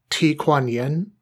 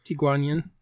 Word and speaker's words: Ti Kuan Yin: But it was Ti Kuan Yin, I think is the right pronunciation. – Yeah. Ti Kuan Yin.